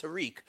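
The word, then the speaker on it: Tarik: I hear his first name is pronounced Tariq.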